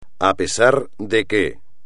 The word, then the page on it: a pesar de que: espagnol